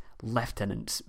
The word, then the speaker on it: lieutenant: but it seems that a Germanic reading of the U led lieutenant to be pronounced leftenant by the English. It's really strange, and it's the only word I know of with a hidden F disguised as a U in the English language, but if you know of any others, then please send me an email because I'm really interested in this.